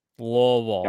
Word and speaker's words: Louisville: So first and foremost, what we have to get out, it's not Louisville, Louisville. – Louisville. – Gotta say it right.